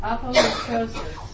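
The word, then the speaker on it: apolutrosis (ἀπολύτρωσις): And the first word I want to look at in the New Testament is pronounced apolutrosis, A-P-O-L-U-T-R-O-S-I-S.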